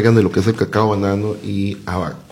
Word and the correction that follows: abacá: Y abacá, perdón.